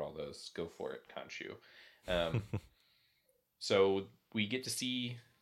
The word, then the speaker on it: Khonshu: Khonshu.